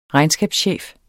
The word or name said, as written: regnskabschef